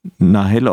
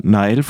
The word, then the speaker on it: Nahel: Äh, wir wollten nochmal sprechen über den Tod von Nahel oder Nahel, französisch ausgesprochen, diesem Jugendlichen, der von einem Polizisten erschossen wurde.